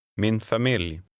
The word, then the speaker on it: familj: The L in the last word is pronounced. Try to read number three. – Min familj.